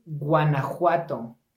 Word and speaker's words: Guanajuato: It is Guanajuato. – Guanajuato.